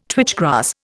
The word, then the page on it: twitch grass: Indian